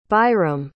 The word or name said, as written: byram